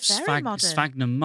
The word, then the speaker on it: sphagnum: And then there's an artist's impression of the design for the new Ilkley Fountain, which is based on, and I don't know how to pronounce this, sphagnum moss. – Yeah, that is sphagnum.